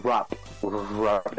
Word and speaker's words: wrap: ¿Cómo se dice? Un wrap. – Wrap. – Es como una R al principio.